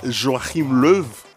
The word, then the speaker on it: Joachim Löw: L'Allemagne qui maintient sa confiance à Joachim Löw. – Ah ouais, on m'a dit que c'était ce qu'il faut le prononcer. – Belle prononciation.